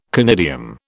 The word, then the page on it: conidium: British English